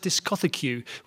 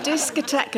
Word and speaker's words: discotheque: In fact, I know a boy who thought the word was pronounced discothecue, which has always stuck in my memory. – Discotheque and discothecue are actually the same word. – Yes, pronounced differently.